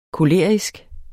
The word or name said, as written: kolerisk